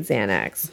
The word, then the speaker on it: Xanax: What does Xanax start with? Does that start with an X? – It's spelled with an X, but it makes the Z sound.